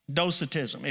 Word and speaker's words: Docetism: Docetism. If you're taking notes, you can spell it by D-O-C-E-T-I-S-M.